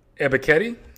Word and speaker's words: Ebiketie: Second pick, Arnold Ebiketie.